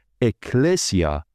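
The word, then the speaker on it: اکلسیا (ἐκκλησία): نخست باید دانست که کلمه کلیسا یا کلیسیا در زبان فارسی در اصل واژه‌ای از یونانی و تلفظ آن اکلسیا می باشد.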